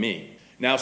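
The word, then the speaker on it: meno (μένω): No more can ye except ye abide in me. Now, Strong's Concordance tells us that the Greek word for abide in this verse, it looks like this.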